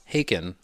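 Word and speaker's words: Häcken: Just as to round this off, by the way, Hacken is actually pronounced Haken, like taken or bacon.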